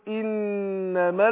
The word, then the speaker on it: انما: يبقى الهمزة المكسورة المبدوء بها تنطق زي الاي مش زي الاي، خلاص. فاقول ايه انما المسيح، النون برضو مشددة، النون عندنا في انما مشددة هتاخد غنة ايه حركتين، فاقول انما المسيح.